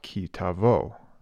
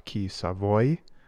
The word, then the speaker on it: Ki Tavo: I'm your host, and this week I'll be addressing Parashat Ki Tavo, another way you may hear it pronounced, Parashat Ki Savoy, which is Hebrew for when you enter.